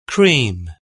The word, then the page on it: cream: British English